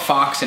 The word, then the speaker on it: Fawkes: So anyway, I thought the story was pretty cool, but V for Vendetta really like glorified Guy Fox, and it's spelled Fawkes.